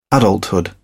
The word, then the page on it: adulthood: British English